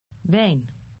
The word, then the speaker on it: wijn: Wijn. Wijn. – Note that the word wine in Dutch begins with a V sound, as in the English word wine.